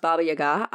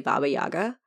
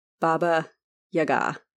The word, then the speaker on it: Baba Yaga: I learned that it's pronounced Baba Yaga. I will forget many times and just say Baba Yaga, but it's supposed to be emphasis on the first ba and the second ga. Baba Yaga.